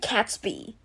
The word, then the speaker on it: Gatsby: The Great Gatsby. – Yeah. Emma 的读音才是正确的读音。The Great Gatsby， The Great Gatsby.